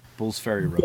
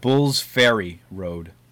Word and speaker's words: Bull's Ferry Road: Bull's Ferry Road. – Bullshitting Road? – Bull's Ferry Road. Ferry as in like a boat ferry.